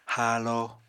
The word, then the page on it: haalo: Apatani